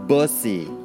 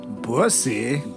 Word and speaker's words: bussy: The next term is pronounced bussy. – pussy. – B as in boy. U-S-S-Y. Bussy.